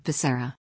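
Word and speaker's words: Becerra: "I nominate Xavier Bocarria," Biden said, before he paused and attempted to correct himself, only to mispronounce it again, saying Bashara. Bashara pronounces his name like Basara.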